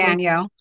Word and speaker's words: Guadagno: Guadagno.